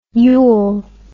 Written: ule

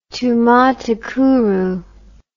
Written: tumatakuru

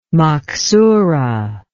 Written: maksoorah

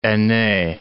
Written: aine

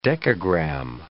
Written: dekagram

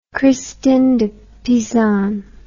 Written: Christine de Pisan

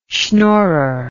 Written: shnorrer

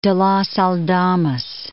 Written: Leon de los Aldamas

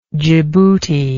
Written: djiboutian

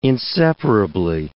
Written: inseparably

